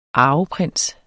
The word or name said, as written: arveprins